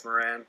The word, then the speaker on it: Morin: Sam Morin, or how do you say it, Moran? – Yeah, I say Morin, but, like, the broadcast crew says Moran.